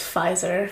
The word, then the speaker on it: Pfizer: I'm a visor queen now. Did I say that right? – No, it's Pfizer.